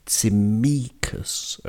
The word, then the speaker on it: Tsimikas: Note the pronunciation, there's a stress on the middle syllable. Tsimikas, okay, be careful with that one.